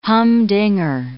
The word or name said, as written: humdinger